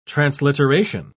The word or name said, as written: transliteration